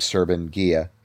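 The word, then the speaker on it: Serban Ghenea: There's a guy named Serban Gia, I think. – Yep. – That's how you pronounce his name.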